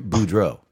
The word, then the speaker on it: Boudreaux: Well, anyways, speaking of people who don't have back problems and are probably very strong, the former Harland Parker Bordeaux. Bordeaux? How the fuck do you say his name? Help me out here, sir. – Bordeaux? Oh, I'm sorry, Boudreaux. Parker oh, Boudreaux.